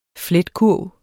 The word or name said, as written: fletkurv